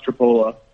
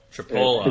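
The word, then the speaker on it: Tripoli: It's pronounced Tripola. – Tripola. – It's pronounced not the way that it actually looks.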